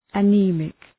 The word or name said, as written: anemic